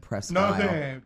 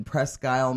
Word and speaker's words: Presque Isle: and it was it was in Presque Isle, Maine. Mm. Call it Presquizzle. It's spelled I-S-L-E.